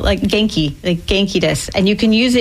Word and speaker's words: genki: How do you pronounce that? – Genki. – Genki. Genki. – Like with a hard G. Hard G.